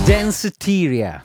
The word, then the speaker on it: Danceteria: Dammi la pronuncia corretta, vai! – Danceteria.